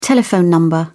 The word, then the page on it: telephone number: British English